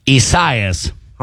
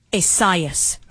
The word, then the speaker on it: Isaias: Isaias. – Okay. I've heard it two different ways now, but according to our good computer here. – Isaias. – Okay. – Isaias sounds like a character in the Odyssey.